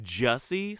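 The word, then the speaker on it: Jussie: Oh, sorry, is it Jesse? – It's supposedly pronounced Jesse Smollett, yes.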